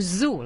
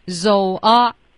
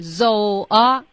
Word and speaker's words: zoo: 对，有趣吧同学们啊， zoo 是吧， zoo 这词我们认识，确实是表动物，但是它放在词首的读音呢就绝不是 zoo 了，它是 zoa, 注意它是 zoa 这个读音、 zoophagus 是食肉的，吃肉的，学会了吗？